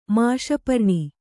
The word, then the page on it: ಮಾಷಪರ್ಣಿ: māṣa parṇi